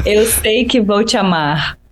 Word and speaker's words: Eu Sei Que Vou Te Amar: It's called Eu Sei Que Vou Te Amar.